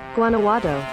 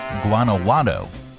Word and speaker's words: Guanajuato: Guanajuato. – Guanajuato. Okay. Say it again. – Guanajuato.